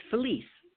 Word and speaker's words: Felicia: It's Felicia, just like Denise.